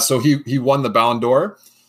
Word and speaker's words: Ballon d'Or: who Ballon d'Or, is that how you say it? Ballon d'Or, excuse me.